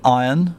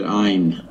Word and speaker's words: Ayn: I call it Ayn, because that's how it's written. Do you pronounce it Iron? – Well, the American I was listening to said Ayn.